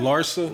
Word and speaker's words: Larsa: How you pronounce her name? – Larsa.